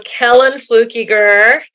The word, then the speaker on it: Kellan Fluckiger: Well, Kellan Fluckiger, did I say it right finally? – You said it perfect.